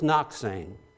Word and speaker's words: Nokseng: His name, and it's spelled N-O-K-S-E-N-G, pronounced Noxang.